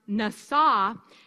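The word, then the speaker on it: Nasa (נסה): And for one thing, I was pronouncing it incorrectly. Wouldn't you know, it was a Hebrew word. And actually, it's pronounced Nasa, and it means to test, to prove.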